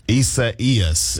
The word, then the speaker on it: Isaias: Isaias is how you say it.